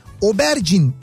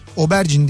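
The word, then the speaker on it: aubergine: İngiltere'de ise e, bu arada e, İngiltere'de Obergin e, diye okunuyormuş. Obergin deniyormuş. ismi oymuş. – Obergin? Patlıcan. Evet evet. – Obergin diye telaffuz ediliyormuş. Aubergin diye yazılıyor.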